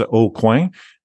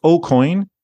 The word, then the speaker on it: Aucoin: If you're French, it's Aucoin, at the corner. Uh-huh. But if you're English, it's Aucoin. And it's spelled Aucoin.